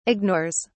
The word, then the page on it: ignores: British English